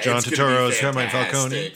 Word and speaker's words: Turturro: I'm going to mispronounce his name like crazy, but I love him. I've loved him since Big Lebowski. John Turturro. – Turturro. – Okay, I wasn't that close. Yeah. Close. That was close.